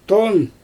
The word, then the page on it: ton: Xyzyl